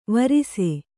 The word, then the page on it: ವರಿಸು: Kannada